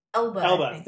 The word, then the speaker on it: Elba: And this is sort of a very deliberate and I think well cast, you know, change to have Idris Elba. Did I say that right? – Elba.